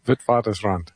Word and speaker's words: Witwatersrand: How do you say it again? – Witwatersrand. – Yeah, because you speak a little Afrikaans. For me, it's Witwatersrand. But the,